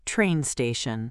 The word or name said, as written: train station